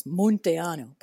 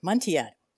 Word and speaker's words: Munteanu: It is meant to be in Romanian pronounced with that U the way it is, Munteanu, but I never pronounced it that way. I pronounce it Munteanu. I've anglicized it.